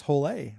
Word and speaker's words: Hole: It would be pronounced Hole in his native culture.